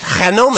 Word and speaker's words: GNOME: GNOME. – Did you know that the Spanish pronounce it Genome.